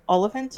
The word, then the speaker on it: Oliphant: Oily font? Oily font? That's how you say it? Oily font? – Like elephant, except an O, so Oliphant.